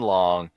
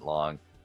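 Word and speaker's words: Leong: Great, the drag racing great, Roland Long, that's spelled L-E-O-N-G. I've always pronounced it Long. I've spoken to him. He didn't correct me, so maybe that's the way you say it.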